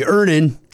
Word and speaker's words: Earnin: I'm going to pronounce this properly. Earnin. – E-A-R-N-I-N is pronounced Earnin. I don't know how else you would pronounce it, but they really want to be clear that that's how to pronounce it.